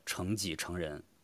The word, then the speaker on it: 成己成人: 呃，上面有一个陈己成人，其实应该是念作成己成人，因为我们都来自成城中学。